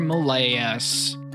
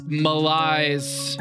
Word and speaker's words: malaise: Kyrie Irving, LeBron James, Adam Silver, and the NBA's PR malaise. – Malaise. – That was a really bad pronunciation. You got to do that again. – Malaise.